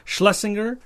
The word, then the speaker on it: Schlesinger: So Marathon Man was written by William Goldman, and it was directed by John Schlesinger? Schlesinger.